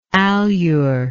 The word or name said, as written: alure